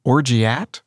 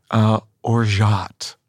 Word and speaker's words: Orgeat: It looks like you would say it Orgeat. The pronunciation that is correct is think of Zsa Zsa Gabor. – Okay. – As you're saying this. Orgeat.